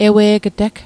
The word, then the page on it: ewe'g'teg: Mi'gmaq